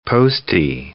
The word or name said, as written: postie